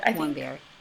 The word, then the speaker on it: Warmbier: Warmbier.